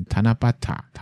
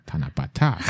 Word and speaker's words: 七夕: 嗯，它虽然日文是写成七夕，可是却念成塔那巴塔，塔那巴塔，塔那巴塔。